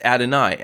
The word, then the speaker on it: Adonai: In fact, the vowels that occur underneath that four-letter word of God, the tetragrammaton, is pronounced Adonai, and the vowels reflect saying reflect the pronunciation of Adonai, meaning Lord or Master.